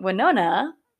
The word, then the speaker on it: Winona: But instead of saying Winona, which is the correct way to pronounce her name, he starts screaming, "Look, it's Wiona."